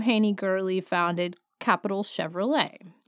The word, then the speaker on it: Gourley: Haney Gurley, I think it's pronounced Gurley. It's spelled G-O-U-R-L-E-Y. So if I got it wrong, I'm sorry, but I've always heard it pronounced Gurley, so that's where we're going.